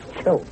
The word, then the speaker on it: Choate: It's Choate. Spell it. – C-H-O-A-T-E.